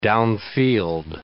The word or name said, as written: downfield